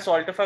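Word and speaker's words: Saltafa: मैं सॉल्टफा का भी यूं करता हूँ कि सॉल्ट नमक आफा, because everybody pronounces it different, obviously it's a made up word, तो सल्ताफा पता नहीं क्या क्या अजीब अजीब.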